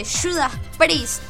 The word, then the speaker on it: Judas Priest: Claro, bueno, después también vamos a estar escuchando un discazo en vivo como de Judas Priest, ¿no? – Sí.